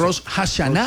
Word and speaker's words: Rosh Hashanah: Rosh Hashanah.